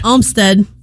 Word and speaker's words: Umstead: Umstead. Yeah. Oh, it's German.